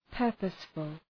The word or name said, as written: purposeful